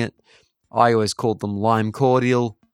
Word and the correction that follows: Cordiale: My girlfriend corrected me recently and said that it's actually Lime Cordiale and it is spelled Lime Cordiale.